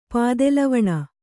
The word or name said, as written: ಪಾದೆಲವಣ